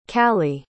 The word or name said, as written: caleigh